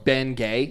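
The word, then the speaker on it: Bengay: I do want to say, I did not know that Bingay was just spelled like that. – Yeah. – Like it's literally just like Benjamin is gay. – Yep. – It's literally how it's spelled. Bingay. – Yeah. – Oh, B-N-G-A-Y.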